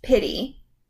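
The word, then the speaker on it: Pittee: Pitty. And it's P-I-T-T-E-E. – Yeah. – But it's pronounced like P-I-T-Y.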